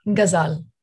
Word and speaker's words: Ghazal: So the Ghazal, is it Ghazal? How do you pronounce it? – Ghazal. – Ghazal, good, got that right.